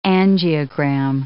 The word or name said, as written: angiogram